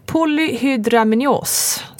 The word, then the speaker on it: polyhydramnios: Och anledningen till igångsättningen är polyhydramnios.